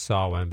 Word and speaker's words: Samhain: Because it's pronounced Samhain, but it's spelled S-A-M, if you're looking it up, H-A-I-N or S-A-M-A-I-N. It's actually spelled both ways.